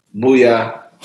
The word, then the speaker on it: Mwiya: Mwiya.